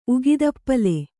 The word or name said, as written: ಉಗಿದಪ್ಪಲೆ